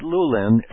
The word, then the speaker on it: Lulin: So Comet Lulin, L-U-L-I-N is spelled, Comet Lulin, L-U-L-I-N, and its official designation is C-2007-N3.